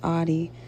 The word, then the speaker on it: Addie: So just to clear things up, my name is actually pronounced Addie, like odd Addie.